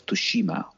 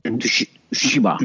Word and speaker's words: Tsushima: la fecha de lanzamiento del Ghost of Tsushima, o Tutushima, o cómo que se dice, ¿cómo se dice? Tsushima.